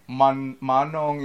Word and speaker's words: Manong: Manong is the right way to pronounce it.